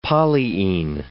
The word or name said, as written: polyene